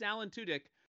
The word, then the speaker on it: Alan Tudyk: But no, on Twitter, confirmed by the man himself, it is Alan Tudyk.